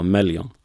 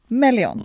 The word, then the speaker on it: Melyon: Hur uttalar du varumärket? För jag vill inte säga fel. – Ja, Melion. – Melion. Det är mm. inte svårare än så.